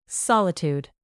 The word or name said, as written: solitude